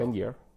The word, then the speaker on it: Lanier: Jaron Lanier? – Ja, Ein VR. – Lanier oder ja. – Lanier.